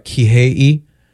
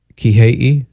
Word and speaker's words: Kihei: All right, Kihei. Kihei. – Perfection. – Am I butchering it? – You're asking me.